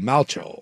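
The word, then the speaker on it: Malchow: They're saying it's pronounced like the Replacements quarterback Shane Falco, so Malcho.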